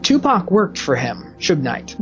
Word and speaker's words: Suge Knight: Suge Knight.